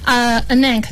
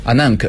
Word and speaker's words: Anank: 乐队的名字呢叫做正确念法也怎么念？Anank。 – Anank，对，没错。 – Anank，然后翻译过来叫做阿南克。